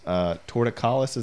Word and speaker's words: torticollis: Torticollis, is that how you say it? – Yeah.